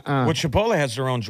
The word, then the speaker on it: Chipotle: So he's an insider. He pronounces it the right way. – Chipotle.